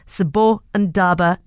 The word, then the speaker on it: Sbondaba: If you'd like to read up more about their story, it's spelled S-B-O-N-D-A-B-A Dance. Sibondaba Dance.